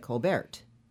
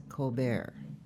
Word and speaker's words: Colbert: And Jean Harlow kept referring to her as Claudette Colbert, pronouncing the T. And so Claudette goes up to Jean and she says, "Darling, the name is Colbert. The T is silent, just like in Harlow."